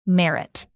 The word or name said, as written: merit